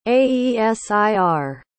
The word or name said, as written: aesir